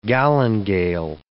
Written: galingale

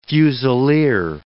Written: fusileer

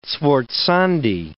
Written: sforzandi

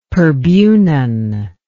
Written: Perbunan

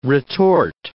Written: retort

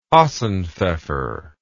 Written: hassenpfeffer

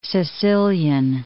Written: Sicilian

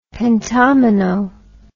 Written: pentomino

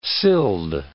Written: sild

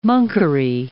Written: monkery

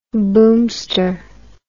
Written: boomster

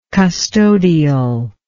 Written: custodial

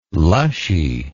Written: lushy

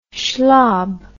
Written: schlob

